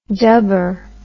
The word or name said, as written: dubber